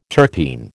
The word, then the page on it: terpene: American English